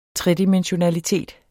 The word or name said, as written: tredimensionalitet